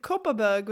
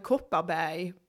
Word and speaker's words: Kopparberg: But the thing is, whenever I ordered it and I pronounced it in Swedish, the way it sounds, I say, could I have a Kopparberg, please? The people working in the restaurants and bars wouldn't understand what I meant. So I had to pronounce it the way it's pronounced in the UK.